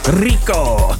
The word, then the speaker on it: Rico: You have to like roll your R when you say that, you know, like a sort of Latin Spanish vibe. Rico.